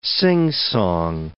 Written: singsong

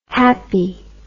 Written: happi

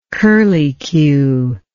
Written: curlycue

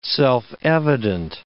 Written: self-evident

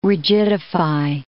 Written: rigidify

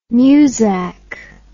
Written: musak

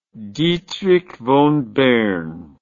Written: Dietrich von Bern

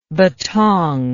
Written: bettong

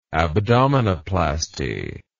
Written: abdominoplasty

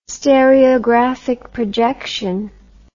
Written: stereographic projection

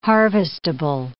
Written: harvestable

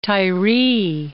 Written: Tyree, Mount

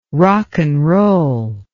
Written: rocknroll

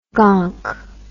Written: gonk

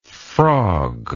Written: frogged

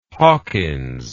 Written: Hawkyns

